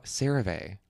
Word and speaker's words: CeraVe: How do you say it? – I don't. CeraVe. – Oh no. – Maybe it's CeraVe. I think CeraVe sounds way better.